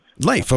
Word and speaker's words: Leif: Now, is your name pronounced Leif or Leif? – Leif, okay. Like Leif Garrett, the old rocker.